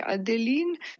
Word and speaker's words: Adeline: Ob rojstvu svoje prve ščerke Adeline, po francoski izgovorjavi.